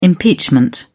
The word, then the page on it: impeachment: British English